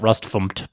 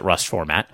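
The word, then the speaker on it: rustfmt: Rust Format, which is spelled Rust FMT, sometimes people pronounce it Rust FMT, but I pronounce it Rust Format, is an auto-formatting tool, and I mentioned it on the show in the past.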